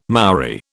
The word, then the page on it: Maori: British English